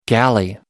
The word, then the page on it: galley: American English